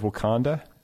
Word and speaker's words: Wakanda: We have World of Wakanda. Am I pronouncing that right? – Yes, you are.